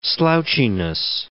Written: slouchiness

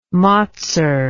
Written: motser

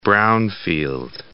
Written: bohrium

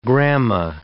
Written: grama

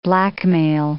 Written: blackmail

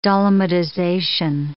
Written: dolomitization

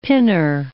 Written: pinner

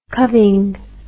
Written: cubbing

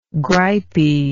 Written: gripey

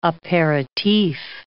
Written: aperitif

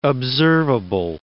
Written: observable

